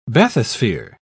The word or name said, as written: bathysphere